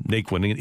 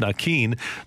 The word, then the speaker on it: Naquin: even though everybody calls him Naquin, including him, but apparently, Joey Votto said, no, his name is supposed to be pronounced Nakeen.